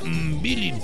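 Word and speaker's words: Mbilli: Cuando Saúl el Canelo Álvarez se enfrente a Cristian Mibil. – Se pronuncia Mbilin. – Ah, sí, cierto. Mbilin. Gracias.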